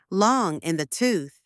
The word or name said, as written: long in the tooth